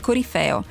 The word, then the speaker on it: corifeo: Oh, non solo me l'ha detto Pietro La Corte in regia, che lo sapeva perfettamente, ma ho controllato sul DOP, sul dizionario d'ortografia e pronuncia, che è la Bibbia. – Bible, la Bibbia, non si scappa. Niente, corifeo. Che dobbiamo dire? Aldo Busi ha sbagliato.